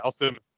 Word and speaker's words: Al Simmons: I'm saying this right. It's Al Simmons, right? – Yep. – Yeah, Al Simmons.